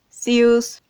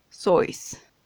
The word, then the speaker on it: Seuss: Así es, pues hoy te propongo que hablemos sobre Dr. Seuss, sius depende de en qué parte del mundo estés, su apellido y su nombre, digamos, artístico, cada quien lo pronuncia como se le da la gana, pero al ser de origen alemán, pues en realidad la pronunciación correcta debería ser sois.